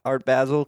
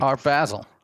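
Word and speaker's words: Art Basel: Uh, yeah, it is pronounced Art Art Basil because I remember there's a Pusha T line that goes something like Art Basil. – Art Basil and the bezel.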